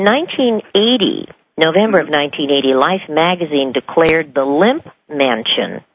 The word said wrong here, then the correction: Lemp: Is that Limp or Lemp? – It's LEMP, LEMP. – LEMP. I apologize for the mispronunciation.